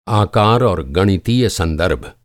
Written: गणितीय

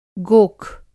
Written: गोख

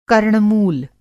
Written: कर्णमूल

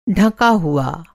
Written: ढका हुआ

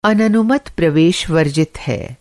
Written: अननुमत

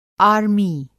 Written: आमीं

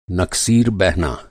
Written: नकसीर

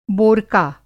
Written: बोरका

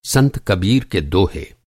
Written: संत

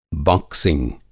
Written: बाक्सिंग

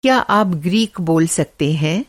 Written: ग्रीक

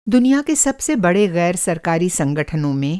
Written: ग़ैर सरकारी संगठन

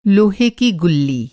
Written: गुल्ली

